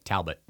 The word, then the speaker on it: Talbot: Did I say it right? – Talbot, yes.